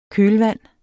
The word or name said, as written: kølvand